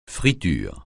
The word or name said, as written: friture